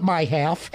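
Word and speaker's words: MIHAF: Like everything in Michigan government, you pronounce the MI as my. – So, of course. – So we call it my half.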